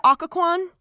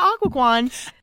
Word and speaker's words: Occoquan: They haul Alice and Emily into Occoquan. Did I say it right that time? – Yes. – Okay. They haul them into Occoquan.